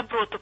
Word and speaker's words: доброту: Доброту, правильно. Тут и ударение, простите меня. – Прощаю. – Ударение, доброту, да, простите.